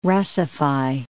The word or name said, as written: Russify